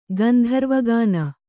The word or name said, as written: ಗಂಧರ್ವಗಾನ